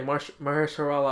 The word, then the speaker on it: Mahershala: Yeah, Mahershala Ali.